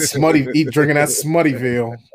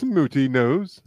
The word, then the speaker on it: Smuttynose: Drinking that Smuttyville, snobby ass. – It's pronounced Smooty Nose.